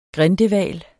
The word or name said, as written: grindehval